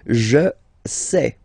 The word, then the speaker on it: sais: Je sais. Okay, final S not pronounced, and then you get this A I E.